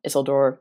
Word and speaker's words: Isildur: Isildur, which also I might be pronouncing completely wrong, but feel free to correct me there. – That's what they say in the Rings of the Power, so.